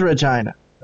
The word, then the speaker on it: Regina: It is Regina. – It is. That's really how it's pronounced.